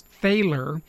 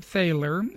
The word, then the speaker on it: Thaler: And he writes this article about aspects of the work of Richard, I think it's pronounced Thaler. It's T-H-A-L-E-R. So I think it's Thaler.